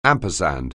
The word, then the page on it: ampersand: anglais